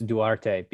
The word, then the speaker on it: Duarte: Well, since he's Dominican, it's Duarte, because in Spanish, you pronounce all of the vowels in Spanish and you're usually good to go.